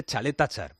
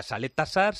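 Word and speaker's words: Čaleta-Car: y efectivamente al final de su propia boca ya sabemos que se dice Chaleta Char, porque claro, se escribe Caleta Car, hay quien decía Saleta Sar, Saleta Char, Chaleta Sar, pues se dice Chaleta Char, por lo menos pronunciado en croata.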